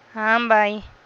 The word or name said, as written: am bay